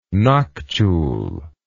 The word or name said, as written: noctule